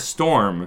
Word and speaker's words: Storm: Well, that's like, so my last name is Storm. It was shortened from Strumlauf at Ellis Island, which I'm glad because I would have never been able to spell it. But like, I still get people who screw up Storm. Like, they'll say Strum or something else.